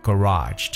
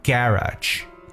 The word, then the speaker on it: garage: garage that's G A R A G E garage 这个词很有意思，它有很多发音，可以叫 garage garage or garage garage。